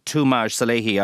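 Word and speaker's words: Toomaj Salehi: Will you tell us about Tumaj Salehi? I hope I'm saying his name correctly. – Yes, you are indeed.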